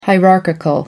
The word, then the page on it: hierarchical: American English